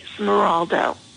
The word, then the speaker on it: Smiroldo: Smiroldo.